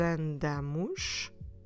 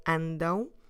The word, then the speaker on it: andam: So, don't forget that to pronounce this sound, you need to close your lips in the end. So you stress not that last syllable, but the one before, andam. Andam. But then at the end, close the lips.